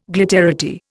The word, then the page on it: glitterati: Indian